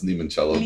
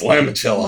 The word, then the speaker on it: limoncello: And limoncello in, it's actually pronounced limoncello, but we're in the United States. – We're in Ohio. – So it's limoncello.